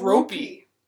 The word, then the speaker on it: Roope: Anonymous said to Misunderestimated, just want every Dallas fan to know that our new favorite boy, Roope Hintz, name is pronounced like rope with a long O and an E-Y at the end. E-Y. So it's ropey.